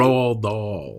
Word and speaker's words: Roald Dahl: The author of The Witches, Roald Dahl? How do you say his name? – Roald Dahl.